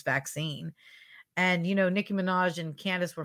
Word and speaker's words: Minaj: I'm not sure how long they can keep this narrative going, but Nikki, you know who Nicki Minaj is? Is that how you say her name? – Minaj.